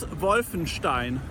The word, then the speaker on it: Wolfenstein: And so the Castle Wolfenstein, or in real German, Schloss Wolfenstein.